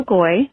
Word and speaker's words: Geu: My name is Nicole Goy. That's spelled G-E-U.